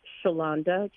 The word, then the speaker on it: Shalanda: It's pronounced Shalanda, just like Yolanda.